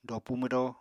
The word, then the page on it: dopu mwdóo: apatani